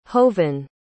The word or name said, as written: hovan